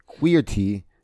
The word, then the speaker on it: Queerty: But look at that in an in an interview with Queerly, oh sorry, Queerty. Yeah,